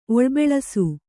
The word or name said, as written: ಒಳ್ಬೆಳಸು